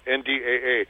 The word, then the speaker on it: Nda: NDAA, in Apache that word means Nda. You say Nda. Yeah. It's a long A, Nda, and that word Nda means enemy.